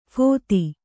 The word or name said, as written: ಫತ್ತೆ